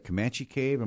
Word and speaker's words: Comanche Cave: Also, you have back at, what is it, Comanche Cave? Am I saying that right? – Yes.